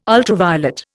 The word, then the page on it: ultraviolet: Indian